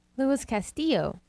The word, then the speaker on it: Castillo: Say his name for me. – Luis Castillo. – There we go. – Double L means it's a Y sound.